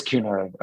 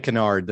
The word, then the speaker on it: Cunard: So it is Cunard. Okay. Now you know. – Cunard. Okay. But he's going to say Kennard the very next time. – I probably will pronounce it Kennard.